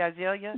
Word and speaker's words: Azalea: Am I pronouncing it correctly, Azalea? – Yeah, yes.